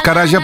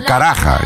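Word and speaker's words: Karajan: Y decimos Caraya porque si lo pronunciáramos tal como se escribe en castellano, sería Caraja.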